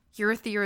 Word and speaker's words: Urithiru: No, that's too many syllables. – No, that was right. It's Urithiru. – Okay. – And Capsule's like, Urithiru is just a fable.